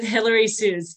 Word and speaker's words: Hillary Susz: And today I have the pleasure of virtually chatting with Hillary Susz. Did I pronounce that correctly? I should have asked that before. – Yeah, it's Susz like Dr. Susz.